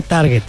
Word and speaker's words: target: Se dice target. Sí, a ver, aquí está el target. No, es target. En inglés se pronuncia target.